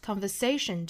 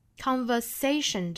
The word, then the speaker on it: conversation: During the last conversation，后面这个 the 需要做弱读，the the the during the during the last conversation，这个 last 注意元音，conversation 这个单词呢，中间的这个 s 要读成一个清辅音的 station，不要把它读成了 conversation 啊，应该是 station。